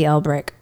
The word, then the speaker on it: Elbrick: Xanthi Elbrick Photography.